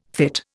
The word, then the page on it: fytte: Indian